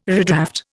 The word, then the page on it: redraft: Indian